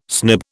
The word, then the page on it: snib: British English